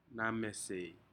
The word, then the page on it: namesei: Aneityum